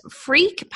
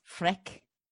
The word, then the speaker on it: Freke: It's spelled F-R-E-K-E. So I'm saying freak, but it sounds so wrong. – Freck. – Freck? Let's go Freck.